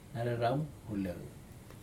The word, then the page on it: nareramuleiu: Aneityum